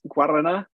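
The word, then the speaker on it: Guaraná: And you showed me the most amazing drink in the world, which is Guarana.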